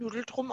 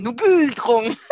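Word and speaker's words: Ngultrum: Ich finde Nugeltrum aber ein bisschen süß. – Nugeltrum. Aber N-G-U-L. Nugeltrum. – Nugeltrum. Ah, es kann Nugeltrum.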